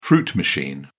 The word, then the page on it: fruit machine: British English